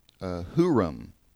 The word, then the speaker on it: Huram: And it can be spelled and pronounced Huram, H-U-R-A-M, which is how it reads in 2 Chronicles.